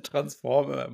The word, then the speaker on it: Transformer: Der Transformer, Moritz. – Ja. Die Betonung liegt auf Trans.